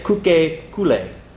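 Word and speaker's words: kuke kule (可口可乐): You say kuke kule. Coca-Cola. That's how you pronounce that.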